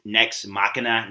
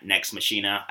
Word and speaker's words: Nex Machina: I mispronounced the name. It's Nex Machina, not Nex Machina.